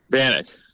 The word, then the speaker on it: Banach: It's Banach? Banach? – Banach. Close. – Oh, Banach. Close. Okay. Wow, the hard CH. Banach.